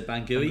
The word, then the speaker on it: Bangui: So question one, my pronunciation might not be quite right. Bangui? – Yeah, B-A-N-G-U-I. I would say Bangui.